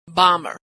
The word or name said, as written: bomber